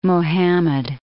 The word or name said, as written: Muhammad